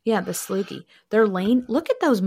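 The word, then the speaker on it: Sloughi: Is a Sloogie. Sloogie. – Mm, that sounds very respiratory. – Oh, yeah, it could be. Rhymes with loogie.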